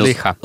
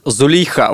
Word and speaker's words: Зулейха: Уже известно, что написать тотальный диктант, автором которого, кстати, стала российская писательница, автор романа «Зулейха открывает глаза». Я правильно произнес? – Зулейха. – Зулейха, вот.